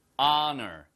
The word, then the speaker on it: honor: honor. 아시겠죠? 그래서 H-O-N-O-R인데 시작이요. honor가 아 발음을 시작하잖아요. 그러니까 what, a, 아, no.